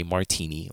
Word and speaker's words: Martini: So when I say, oh, like they'll say, wherever I'm at, DMV, whatever, wherever my name needs to be said aloud, I say Martini like the drink.